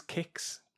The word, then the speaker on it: Qix: it's Quix. It's pronounced really as Kix. Q-I-X.